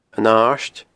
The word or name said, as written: An Aird